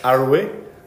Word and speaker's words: Arouet: Yeah, a tiny little bit on François-Marie Arouet. Is that how you pronounce it? – Yeah, I would say so.